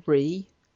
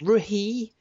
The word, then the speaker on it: Rhee: do you call it Ree or Ray? – I'd say Ree. – Yeah. – Ree. It's spelled R-H-E-E.